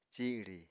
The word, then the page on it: ciri: Apatani